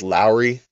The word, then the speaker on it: Lauri: It's spelled Lauri. But it's pronounced Lowry, kind of like Kyle Lowry from the Miami Heat.